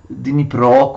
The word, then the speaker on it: Dnipro: discendono il Dniepr, il Dnipro, così lo pronunciano eh, gli ucraini, e conquistano la città di Kiev.